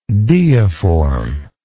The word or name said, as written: deiform